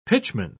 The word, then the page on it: pitchman: British English